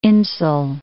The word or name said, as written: Insull